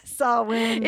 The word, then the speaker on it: Samhain: Yeah, Samhain. – It's spelled S-A-M-H-A-I-N, but the M is silent. – It's an Irish pronunciation.